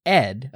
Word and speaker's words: ed: So a few folks definitely wanted to point out that the text editor ED is indeed pronounced E-D. – Got it. Ed. Oh, wait, no, me E-D.